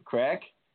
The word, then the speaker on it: craic: It's actually an Irish name. It's spelled C-R-A-I-C. Can you guess how it's pronounced? – C-R-A-I-C, crack? – Yep, crack.